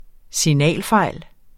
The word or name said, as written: signalfejl